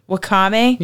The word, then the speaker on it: wakame: I don't know how to pronounce it. – Wakame? Yeah.